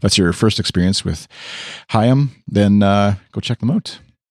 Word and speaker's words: Haim: But, and then at Liren Hollick, we'll say, said, tweeted, small correction. It's Haim, pronounced like Haim.